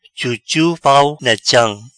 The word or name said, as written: Chuchu pau na cham